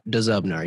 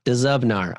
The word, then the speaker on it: Dzubnar: I just Googled how to pronounce Dzubnar, and it is that, Dzubnar. You pronounce duh, then zub, then nar. So I definitely thought you were wrong. I would have guessed the D was silent.